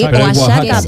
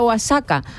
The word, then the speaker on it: Oaxaca: Sí, Oaxaca. Pero se escribe Oaxaca. – Eso. – Exactamente. Con J, pero yo al principio decía Oaxaca. Hasta que un día digo, ah, no, claro.